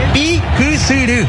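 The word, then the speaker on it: Piquisirí: Que de hecho por eso este escenario lleva ese nombre, es Pikisiri. – Sí, así, señor. Se pronuncia bien, así como dijiste.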